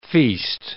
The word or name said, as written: feast